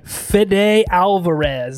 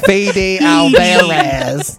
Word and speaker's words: Fede Alvarez: Directed and written by Fede Alvarez. Is that how you say that? – Yep.